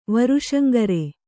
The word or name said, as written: ವರುಷಂಗರೆ